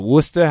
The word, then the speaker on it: Worcester: I'd leave the arena – drive down to Worcester. Is it Worcester? How do you say it? – It's Worcester.